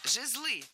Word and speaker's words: жезлы: Жезлы. Неверно. Правильно, жезлы. Ударение в этом слове ставим всегда на первый слог, ведь оно тоже неподвижное. Поэтому жезлы, жезлом, жезлами, жезлов.